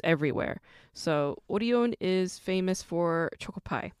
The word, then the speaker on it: Orion: Very similar to Lotte Entertainment and CJ Entertainment, Showbox comes from a food manufacturing company called Orion, or you might pronounce it as Orion, but Orion is how it's pronounced in Korean.